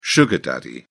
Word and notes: sugar daddy: British English

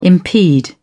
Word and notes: impede: British English

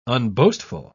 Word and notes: unboastful: British English

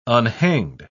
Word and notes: unhanged: British English

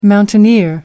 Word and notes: mountaineer: American English

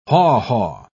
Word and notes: haw-haw: British English

